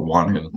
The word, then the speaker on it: whānau: It's pronounced, we call it whānau. That's how it's spelled, which means family.